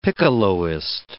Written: piccoloist

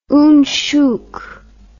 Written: oonchook

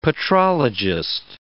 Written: petrologist

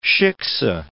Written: shiksa

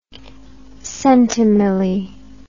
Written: centimilli

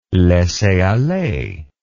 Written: laisser aller

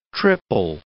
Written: triple

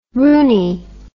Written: rooney

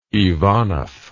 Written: Ivanov

